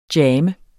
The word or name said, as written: jammer